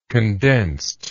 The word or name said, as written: condensed